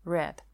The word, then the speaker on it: read: Read глагол неправильный – Read, read, read. I have read the book. Пишется точно так же, произносится абсолютно так же, как слово красный.